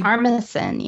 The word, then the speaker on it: Armisen: Fred Armisen? Armistan? How do you say his last name? – Armisen, yeah.